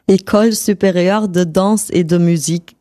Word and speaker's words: École supérieure de danse et de musique: Wie wird das richtig ausgesprochen? – École supérieure de danse et de musique.